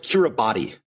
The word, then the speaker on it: Kiribati: But there was one exception to this, and this was the nation of Kiribati, which is spelt Kiribati, but it's pronounced Kiribati, even though there's no S in it, because the T-I is pronounced like an S, and it's a long story.